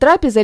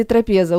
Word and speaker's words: трапеза: Кстати, можно говорить «трапеза» или «трапеза». Ударение можно ставить на любой слог, кроме буквы «Т».